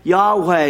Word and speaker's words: Yahweh: The ancient Hebrews used the equivalent of four English letters, YHYH, Yahweh is the way we pronounce it oftentimes.